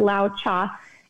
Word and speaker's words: Lao Tzu: And that's from Lao Cha, which you guys have probably seen his name before. He's a Chinese philosopher and it's spelled L-A-O-T-Z-U, but it's pronounced Lao Cha.